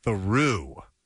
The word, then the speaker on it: Theroux: And in fact, it's not Theroux, it's Theroux. – Theroux. – As in, I walked through the door.